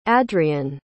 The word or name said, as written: adrion